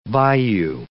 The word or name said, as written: bayou